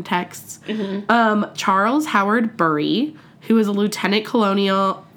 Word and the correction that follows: colonel: Fuck, I always say colonial instead of colonel. – Colonel, yeah. – Lieutenant colonel. – Well, that's stupid. Wherever that's from, that's fucking whoever, stupid. – It's spelled like colonial.